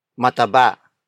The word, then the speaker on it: mataba: Mataba. Mataba. Mataba. Mataba is spelled as M-A-T-A-B-A.